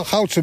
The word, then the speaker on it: Goudse: En zitten dan bij de HEMA echt de Goudaarse mensen, zeg ik dat goed? – Oh ja, Goudse mensen.